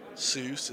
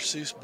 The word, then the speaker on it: Seuss: And I think that Dr. Seuss, I think it's like, accurately is pronounced Seuss is pronounced like choice, so like the correct pronunciation, I think, is supposed to be Dr. Seuss, but that's beside the point.